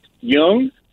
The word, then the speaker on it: Dung: And her name is pronounced Jung. But it's actually spelled D-U-N-G.